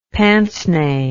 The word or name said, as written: pince nez